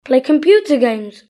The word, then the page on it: play computer games: British English